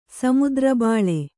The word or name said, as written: ಸಮುದ್ರಬಾಳೆ